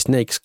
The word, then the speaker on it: Snayx: Alors ça s'écrit S-N-A-Y X, mais ça se dit Snakes comme les serpents justement.